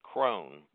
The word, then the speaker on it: Croghan: Uh, I like, of course, George Croghan is a, or some pronounce it Crone, but he he was a Scots Irish.